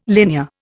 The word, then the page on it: linear: Indian